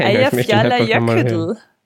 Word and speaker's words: Eyjafjallajökull: Nein, Eyjafjallajökull, glaube ich.